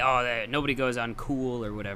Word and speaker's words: Cuil: My topic was on something called Cool Theory, which I thought was named Quill Theory, considering it's spelled C-U-I-L, but it's actually, I found out, pronounced Cool Theory.